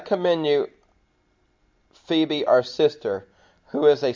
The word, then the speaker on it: Phoebe: and it's Phoebe. When I was younger, I used to try to say it Phoebe. but it's Phoebe, our sister, who is a servant.